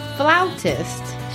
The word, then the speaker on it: flautist: Flautist? She doesn't play the flout. She plays the flute. Oh, she's a flutist. – I worry about your cultural upbringing. It's pronounced flautist.